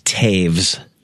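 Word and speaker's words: Toews: It's like T-E-O-W-S. – But it's pronounced Taves. – With like a V sound, T-A-V-E-S? Yep. – Yeah, like rhymes with saves. Yeah, Taves.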